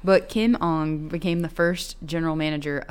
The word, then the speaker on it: Ng: but I think it's Ong. Kind of like Ong, like Aang from like Aang from The Last Airbender.